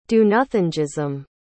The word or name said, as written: do-nothingism